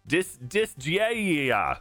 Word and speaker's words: Disgaea: Did I say that right? Is it Disgaea? – Uh-huh. – Disgaea. Is that better? Is that closer? – Yes.